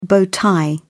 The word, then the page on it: bow tie: British English